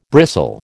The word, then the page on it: bristle: American English